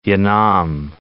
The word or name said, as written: Yanam